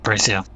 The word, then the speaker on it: Graecia: Graecia.